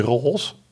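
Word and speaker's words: Rås: Guido ras met een rondje op de A. Dus altijd de Ros.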